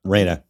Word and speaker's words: Reina: Yep, Reina.